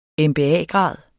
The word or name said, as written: MBA-grad